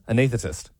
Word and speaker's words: anaesthetist: Yeah, Liam's right, anaesthetist. I think you're overthinking the S in it.